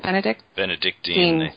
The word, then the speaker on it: Benedictine: It was invented in early 1960s, but it's based in like the practices of Benedict monks. Is that the right? Is that how you say it? – Benedictine. – Yeah, Benedictine.